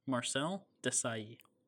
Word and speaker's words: Marcel Desailly: I'm not going to pronounce this correctly. I've been trying. I've been practicing. Marcel Desailly. – You got it.